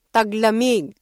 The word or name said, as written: taglamig